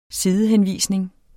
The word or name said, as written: sidehenvisning